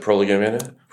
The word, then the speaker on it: Prolegomena: How pronounced pronounce it? Prolegomena. – prolegomena. – Prolegomena, yeah. which is the only the others, but I've just read that, and he he does have in like the first five pages.